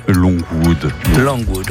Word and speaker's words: Longwood: Longwood. On prononce comme ça, Longwood.